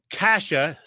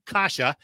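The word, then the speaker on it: Kase: You got forward uh, Andy Andreoff, you got Maxim Sushko, I like that name, David Kasha, spelled like case, but it's David Kasha, and defenseman Derrick Pouliot.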